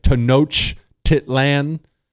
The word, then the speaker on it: Tenochtitlan: It was known as Tenochtitlan. I'm sure I screwed that up, but that's pretty much how you say it. Tenochtitlan.